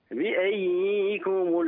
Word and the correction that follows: بأيكم: لا لا، هذه ياء واحدة. نعم كتبت ياءان لكن ننطق بواحدة مشددة. بأيكم المفتون؟ – بأيكم المفتون؟